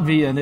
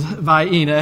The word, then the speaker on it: Vienna: First, the correct pronunciation of said sausages is not Vienna, it is Vienna. It has nothing to do with the city in Austria.